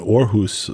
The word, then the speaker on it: Aarhus: Well, you know, I think speaking to that, talking to him about when he was in Aarhus, the orchestra in Denmark.